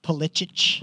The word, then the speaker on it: Pulisic: Great Pulisic's World Cup for me. – Pulisic? – What did I say? – Pulisic. – Pulisic. – That's his Polish name. – That's how you say it.